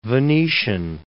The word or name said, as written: Venetian